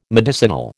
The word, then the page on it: medicinal: American English